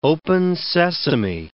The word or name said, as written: open sesame